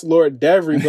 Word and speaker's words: LordEvery: It is pronounced Lord Devery, but it is spelt Lord Every _LordEvery.